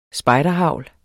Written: spejderhagl

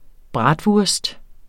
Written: bratwurst

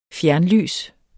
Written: fjernlys

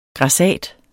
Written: grassat

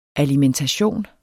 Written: ALIMENTATIONER

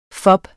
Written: fob

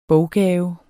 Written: boggave

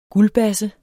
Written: guldbasse

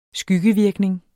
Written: skyggevirkning

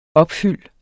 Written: opfyld

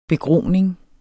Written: begroning

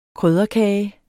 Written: krydderkage

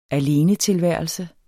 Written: alenetilværelse

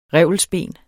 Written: revelsben

